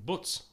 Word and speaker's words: Buc: No, it's BUC. Butz. – It'd be short for something. – Butz is how you pronounce it. It's Ukrainian.